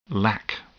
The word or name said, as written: lack